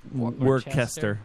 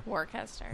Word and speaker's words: Worcester: Worcester? – Worcester. – Worcester.